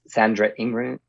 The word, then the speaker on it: Sandra Ingerman: And I think it's Sandra Ingerman.